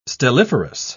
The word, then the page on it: stelliferous: British English